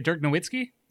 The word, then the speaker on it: Dirk Nowitzki: Dirk, how do you say it? Dirk Nowitzki? – Yep.